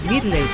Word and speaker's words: medley: Y esta es la presentación que ella hace, que le pusieron de nombre Disco Medley. Medley, ¿cómo se dice? – Medley.